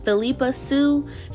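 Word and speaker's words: Phillipa Soo: I know I said it wrong the first time. Philippa Sue, she played Eliza Hamilton, which was Hamilton's wife.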